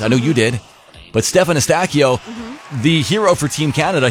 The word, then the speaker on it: Stefan Eustachio: Oh, you were very excited to talk about Stefan Eustachio.